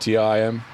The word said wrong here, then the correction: TMI: TMI. – TMI.